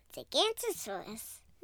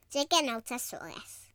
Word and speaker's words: Giganotosaurus: Today I'm going to teach you about Gigantosaurus. Just kidding. It's actually pronounced Giganotosaurus.